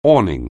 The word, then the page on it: awning: anglais